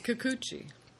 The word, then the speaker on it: Kikuchi: Kikuchi.